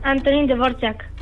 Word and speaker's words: Antonín Dvořák: Antonín Dvořák.